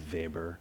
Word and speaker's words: Weber: and I'm taking a lot of these ideas from a German sociologist named Max Weber, or probably pronounced correctly, Max Weber.